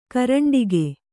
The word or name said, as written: ಕರಂಡಕ